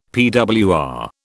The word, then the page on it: PWR: British English